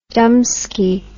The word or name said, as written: dumbski